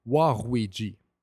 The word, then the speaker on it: Waluigi: Au Japon, le R et le L font le même son. Alors, Luigi peut être prononcé Ruigi. Et puis, Waluigi, donc, peut être prononcé Waruigi. Waruigi est un mélange de Warui et puis de Ruigi.